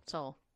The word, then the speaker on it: Seoul: Though it should be noted, they have seen an emerging cluster in Seoul. – Seoul. – That's what I meant. The E is silent, right? – Pretty much.